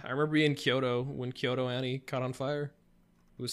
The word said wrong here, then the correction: KyoAni: You say the name wrong every time. – It's Kyoani. Sorry.